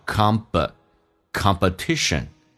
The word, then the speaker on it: competition: 竞赛这个单词在美语当中这个 P 要被浊化叫 competition. Competition.